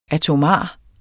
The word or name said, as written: ATOMARE